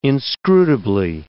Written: inscrutably

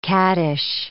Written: caddish

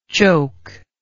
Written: chokeable

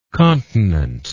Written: continently

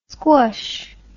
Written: sqush

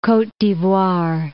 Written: Cote d'Ivoire

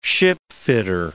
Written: shipfitter

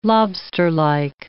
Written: lobsterlike

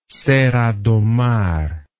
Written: Serra do Mar

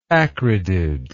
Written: acridid

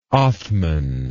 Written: othman

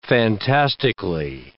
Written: fantastically

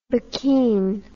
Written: buckeen

